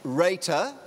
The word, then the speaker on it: Reiter: You pronounce my surname Reiter, like in Ray of Sunshine, not writer.